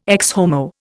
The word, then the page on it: Ecce Homo: Indian